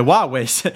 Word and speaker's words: Huawei: Det är inte bolag som till, till exempel Huawei, eller Huawei uttalas det, som tillverkar halvledare.